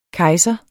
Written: kejser

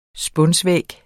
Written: spunsvæg

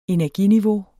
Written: energiniveau

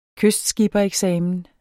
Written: kystskippereksamen

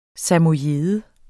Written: samojede

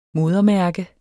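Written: modermærke

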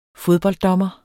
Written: fodbolddommer

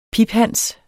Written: piphans

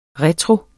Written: retro

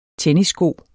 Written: tennissko

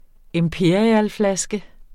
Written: imperialflaske

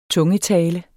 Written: TUNGETALE

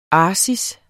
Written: arsis

